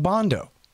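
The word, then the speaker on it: Bandow: All right, you guys, introducing Doug Bondo. Yeah, that's how you say it. Everybody knows that.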